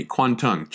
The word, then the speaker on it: Kwantung: Kwantung, K-W-A-N-T-U-N-G. The orthography of this name is kind of strange. What it is a Japanese pronunciation of the Chinese term for the general region. The Chinese pronunciation is Guangdong, east of the pass. East of the pass. Guangdong. And then the Japanese pronunciation of that is the Kanto, Kanto region.